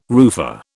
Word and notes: roofer: British English

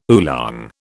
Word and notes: oolong: British English

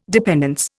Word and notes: dependence: Indian

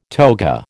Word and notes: toga: American English

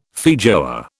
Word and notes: feijoa: British English

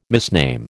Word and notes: misname: American English

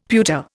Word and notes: pewter: Indian

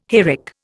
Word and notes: hayrick: Indian

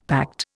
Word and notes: pact: Indian

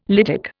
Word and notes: lytic: Indian

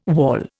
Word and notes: wawl: Indian

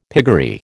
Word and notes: piggery: American English

